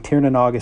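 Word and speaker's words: Tír na nÓg: Again, Tir na n'Og is spelled T-I-R-N-A-N-O-G, kind of like it sounds, which is very rare for a Gaelic term.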